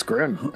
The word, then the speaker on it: Groon: It's Groon. According to my rhyme dictionary, it rhymes with room.